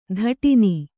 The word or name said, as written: ತಾಡಣ